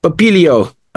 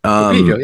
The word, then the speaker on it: Pupillo: Like, it's ridiculous, but Massimo Pupillo. – Pupillo. – Pupillo, yep. – So yeah, I think that's right.